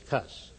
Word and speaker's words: -cus: To this suffix, the prefix C has been added for the sake of euphony, making the full termination C-U-S, pronounced with a hard C. Cus, as a micus, Americanized, it's cuss.